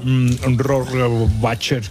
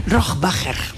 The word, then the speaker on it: Rohrbacher: Bueno, ¿cómo se pronuncia Alba Rohrbacher Kochet? – Rohrbacher. – Rohrbacher.